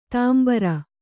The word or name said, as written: ದಾಂಬರು